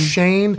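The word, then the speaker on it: Schoen: Spelled Sean, pronounced Shane.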